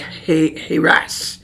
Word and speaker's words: Haras: Now, it's, I'm not sure that I'm pronouncing this right, but it's spelled H-A-R-A-S. And it has a long A sound, so it would be like Haras.